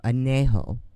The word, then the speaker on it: Añejo: Tequila Arejo. No, Añejo.